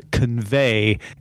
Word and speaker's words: Convai: So they they got together with a company called Convai, except it's spelled V A I huh? – Mm-hmm.